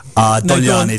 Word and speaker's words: Dogliani: That'll do. Dogliani is official. Official Italian pronunciation, but I've heard far worse over the years.